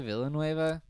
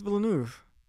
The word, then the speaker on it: Villeneuve: I say Villanueva, but that's totally wrong, so. – I think it's Villeneuve. – I'm sure you're right.